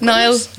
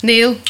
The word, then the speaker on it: Niall: I get a lot of Neils. Yeah. About 50% of people call me Neil, but I can't really complain because Neil is the Irish pronunciation of Niall. Yeah. So you know, like Neil Tobin. So I kind of answer to either. And there's a Neil in work who gets called Niall a lot too.